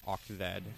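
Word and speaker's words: OXZ: Before that, we heard Oxzed, that's spelled O-X-Z, I just found out it's pronounced Oxzed, with their track Angel, all-girl group out of Osaka that came out in 1981.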